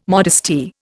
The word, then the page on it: modesty: Indian